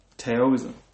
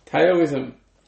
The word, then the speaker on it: Taoism: How do you say it? – Taoism. – Taoism. – T-A-O-I-S-M.